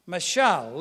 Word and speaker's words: mashal: It is spelled M-A-S-H-A-L in phonetics. Say it in Hebrew, mashal. To be a parable, mashal.